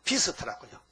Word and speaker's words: beast: 근데 비스터인데 내가 발음을 베스터라 했거든요. 그 영어를 찾아봤더니 좋아요? 영어 따라하시죠. B, E, A, S, T. 그게 우리는 보통 발음을 잘 못하니까 그냥 베스터인가 베스터 아니야. 비스터라고요. 그 발음 부호를 보니까 E, A가 E로 나오더라고요. 이렇게 E, 발음이. 그래서 비스터라 해야 맞지?